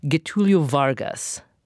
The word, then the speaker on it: Getúlio Vargas: Because tell us the story of, hopefully I'm saying his name properly, Getúlio Vargas.